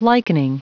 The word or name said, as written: likening